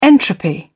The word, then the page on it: entropy: British English